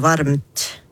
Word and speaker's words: varmt: Så når vi da setter M-en og T-en ved siden av hverandre i ordet varmt, uttalte det sånn som det skrives.